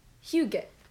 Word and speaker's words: hygge: So, my first word about comforting things is hygge. – Is that how you pronounce it? – Yeah. – That's very good. Hygge. – It's spelled H-Y-G-G-E.